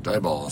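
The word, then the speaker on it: Diboll: Dieball. – It's just Dieball. Yeah. That kids, if you're not from Texas, that's a real place. Dibolll, but pronounced Dieball.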